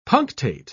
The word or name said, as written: punctate